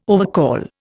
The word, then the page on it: overcall: Indian